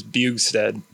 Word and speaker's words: Bjugstad: It's Norwegian, and that J is silent, so it's Bjugstad.